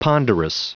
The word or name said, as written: ponderous